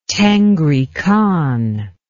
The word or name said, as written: tengri khan